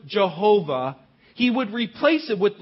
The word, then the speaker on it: Jehovah: The letters in English are YHWH, or it could also be JHWH. We would pronounce it Jehovah.